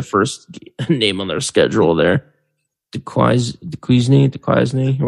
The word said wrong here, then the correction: Duquesne: or whatever. Oh, they're playing Duquesne. Jesus, fuck, I can't say it. Duquesne they open up against, Duquesne.